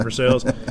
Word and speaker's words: Versailles: Versailles, actually. Versailles? I usually tell people Lexington because I hate having that conversation about why do we pronounce it Versailles.